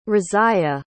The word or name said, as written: Raziya